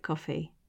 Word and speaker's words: Ko-fi: I think it's called coffee. I think it looks like it's spelled Ko-fi, but I think it's supposed to pronounce it coffee.